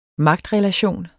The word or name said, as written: magtrelation